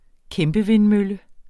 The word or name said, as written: kæmpevindmølle